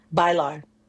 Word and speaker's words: Bailar: Is it pronounced Bailar? – Bailar. – Bailar, which is Spanish for dance.